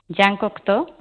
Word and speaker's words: Jean Cocteau: Una frase que a ver si yo digo bien el nombre, Jean Cocteau, ¿cómo se dice? – Sí, Jean Cocteau, sí, bueno, yo tampoco no sé exactamente bien, bien francés.